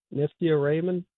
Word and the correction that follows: Nithya Raman: It's pronounced Nithya Raman, but we'll take whatever. I mean, look, if you Nithya Raymond, that's fine too. R-A-M-A-N, man, Raymond.